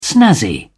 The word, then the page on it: snazzy: British English